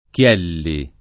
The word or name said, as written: Chielli